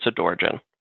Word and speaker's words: Adorjan: Adarjan, is that about right? – Yeah, it's about right. It's Adarjan. – No, so it wasn't right.